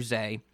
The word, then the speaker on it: Zaeh: It's a duo that goes by Zay Donald. I think Zay is how to spell it. I found like one YouTube person mention it. It wasn't an interview, so I'm not sure, but Z-A-E-H.